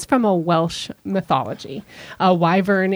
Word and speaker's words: wyvern: It used to be wyver, and then it turned to wyvern. So, in our episode, it's wyvern, but you nailed it on the on the original.